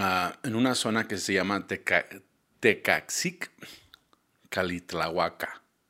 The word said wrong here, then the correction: Calixtlahuaca: ¿sí? Ah, Calixtlahuaca, perdón.